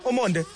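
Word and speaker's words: Ormonde: Well, in Omonde, how do I say it again? Omonde. – You were correct.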